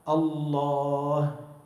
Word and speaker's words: الله: هذه الألف تلفظ ولا تكتب. كيف بتكتب لفظ الجلالة؟ ألف لام لام هاء. بتكتب شيء بين اللام واللام ألف؟ ما بتكتب، لكن بتلفظها، بتقول الله.